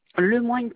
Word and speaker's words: Lemoigne: Lemoigne Paris, L-E, plus loin M-O-I-G-N-E, tiret Paris comme la ville, Carole.